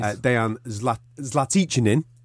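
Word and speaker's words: Dejan Zlatičanin: Dejan Zlatichinin, the new pronunciation as I've been told.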